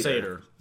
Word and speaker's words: satyr: Or would that be Satyr? – Satyr.